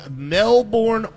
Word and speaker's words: Melbourne: And our special guest, soon-to-be Pro Bowl, special teams punter from Melbourne, Australia.